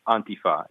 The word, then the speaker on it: Antifa: So if any of your listeners speak Spanish, for example, antifascismo, Antifa.